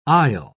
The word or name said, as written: isle